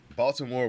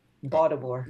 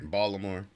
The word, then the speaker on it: Baltimore: Baltimore was on their feet for this one. – You didn't say it right. Baltimore. – Baltimore.